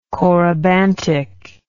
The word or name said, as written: corybantic